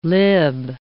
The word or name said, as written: lib